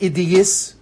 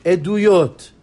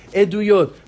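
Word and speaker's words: Eduyot (עדויות): Not Idiyis, not Idiyis. Eduyot. Know how to say the word. Eduyot.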